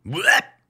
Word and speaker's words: BUAP: En la BUAP. Así se pronuncia, ¿no? BUAP. Me imagino como Yoshi echando un huevo. – BUAP.